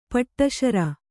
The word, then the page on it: ಪೆಡಸಾರ್: Kannada